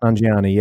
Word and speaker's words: Nanjiani: Nanjiani.